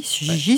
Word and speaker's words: Gigi: Oui, Gigi, ouais, ça se prononce comme ça.